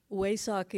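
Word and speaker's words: Vesak: Okay, so very briefly, what WASOC is, it's spelled with a V, but in Pali you pronounce it a W.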